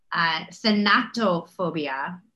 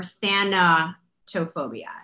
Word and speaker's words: thanatophobia: The fear of dying is, it's pronounced a couple ways, thanatophobia or thanatophobia.